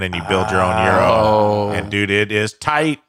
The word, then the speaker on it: gyro: And like they phonetically say it. It's like Y-I-R-O-G-Y-R-O. – Yeah. – Oh, like so Euro, they phonetic, Euro, like, yeah, Euro, Euro. They sound it out for you.